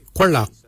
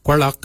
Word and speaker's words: gwelyag: For your hips, we say Kwalak. Kwalak is your hips. Kwalak is spelled G-W-E-L-Y-A-G.